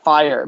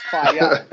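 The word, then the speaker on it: Fyah: Fire, but it's not fire, it's fire. You can't, it's F-Y-A-H. You can't say it's fire, it's fire.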